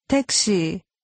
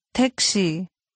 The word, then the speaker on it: 택시: 택시. 시. 택. 택시. – Pay attention to the Korean pronunciation. Taxi.